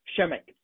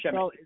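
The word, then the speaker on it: Przemek: Shemek. Pronounced Shemek. It's P-R-Z-E-M-E-K. – Zemek.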